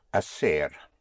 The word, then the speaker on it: hacer: To do or to make is hacer, spelled H-A-C-E-R. Again, the H is not pronounced, so you have hacer, to do.